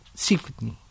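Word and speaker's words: Żywny: Bueno, este es uno que nombramos, que es Sigwini, que puede ser también Sipni, porque se escribe Z-Y-W-N-Y, es Sipni, era violinista, y Elsner era compositor.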